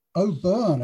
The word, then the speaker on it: O'Byrne: O'Byrne, it's this weird O'Byrne. Irish name.